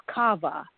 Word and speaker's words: qavah: The Hebrew word for wait is kava, Q-A-V-A-H, and it's pronounced kava in my studies.